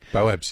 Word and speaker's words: baobab: And how do you say it, baobab tree? – Baobabs, yes.